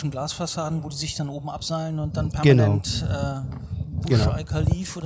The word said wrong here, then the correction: Burj Khalifa: oder wie das höchste Gebäude der Welt, ich hoffe, ich habe es richtig ausgesprochen. – Burj Khalifa, ja.